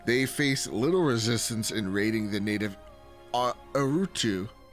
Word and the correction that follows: Utaru: I totally butchered that. – I believe it's Utaru.